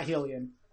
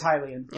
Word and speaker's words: Hylian: It's pronounced Hylian, not Hylian. I've always said Hylian since Ocarina of Time. Nope, it's Hylian.